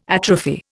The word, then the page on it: atrophy: Indian